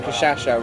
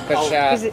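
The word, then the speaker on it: cachaça: Anna yesterday was having a go at you for pronouncing Cachaca wrong because it's Cachaca. – Because he gets sir. – Cachaca. – Kershasa. – He's still doing it. – Kershasa.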